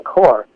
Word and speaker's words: Kaur: All male initiates have to take the name Singh, which means lion, and all women initiates have to take the name Kaur, that's spelled K-A-U-R, Kaur, which means princess or lioness.